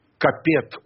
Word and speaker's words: Капет: Капет у нас обычно произносится, да, но, насколько я понимаю, капет, правильно.